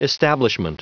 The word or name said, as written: establishment